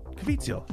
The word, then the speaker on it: Caviezel: Caviezel. That's how you say it. – It's Caviezel. Everybody calls him Jim Caviezel.